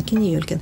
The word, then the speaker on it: Кини Ёлькин: Да, нужно было, чтобы четыре слога, чтобы было, и ударение на третий слог, чтобы было. Вот он как-то сразу моментально, Кини Ёлькин.